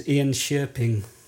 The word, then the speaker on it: Enköping: I've put this into Google Translate, and the way that it's pronounced is entirely different to how it's written. So that K in there, I've written it phonetically as Enköping.